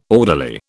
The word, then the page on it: orderly: British English